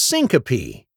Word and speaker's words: syncope: Today's word of the day is syncope. It's spelled S-Y-N-C-O-P-E.